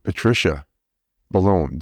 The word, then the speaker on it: Patricia Boulogne: So today I'm interviewing Dr. Pat, or Patricia Ballone.